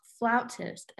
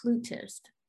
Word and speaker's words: flautist: June 2009, a suburb near London, 20-year-old flautist, and I learned that it is pronounced flautist and not flutist, flautist.